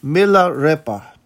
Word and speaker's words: Milarepa: Somebody corrected me in my pronunciation. Life of Milarepa. Milarepa. Milarepa. I mean, I think growing up in a Cuban family, that the e sound is a short sound, but milarepa. It's just harder for me to say.